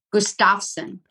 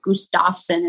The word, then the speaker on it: Gustafson: Gustafson. – Oh, they accent the, they put the wrong emphasis on that syllable. – Yeah, yeah. Well, the Swedish origin of it is Gustaf.